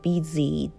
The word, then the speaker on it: busy: Z」の発音も「 G」になりやすいので例えば「 busy」とか「 dizzy」とか「 bg」ではなくて「 busy」「 dizzy」こも「図」に小さい「印が入る感じかな。